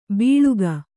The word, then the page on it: ಬೀಳುಗ: Kannada